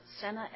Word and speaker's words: Senne: Counsel, you mentioned subsection C and the, you pronounced it Senna, S-E-N-N-E case? – Correct.